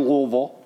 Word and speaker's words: Louisville: Notice how I said that I swallowed my tongue. That's how the locals say it, Louisville, Kentucky.